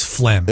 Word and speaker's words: phlegm: Yeah, that silent G really kind of ruins phlegm.